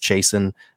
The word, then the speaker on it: Chasson: It is Chasson. We've all been saying it right, so good job everybody.